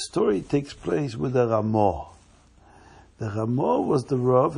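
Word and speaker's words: Ramah (רמ״א): Can you say the word Ramah? Ramah. Ramah stands for three letters. Ramah is spelled Reish Mem Aleph. Reb Moshe Isserles. Reb Moshe, the son of Isser.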